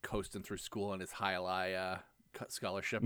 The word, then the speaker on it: Hialeah: They kind of, you kind of drop the A a little bit, like you kind of say Hialeah. – Oh, Hialeah.